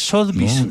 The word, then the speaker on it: Sotheby's: ¿Cómo se pronuncia? Es Sotheby's, no, ¿cierto? – Sí, decirle así.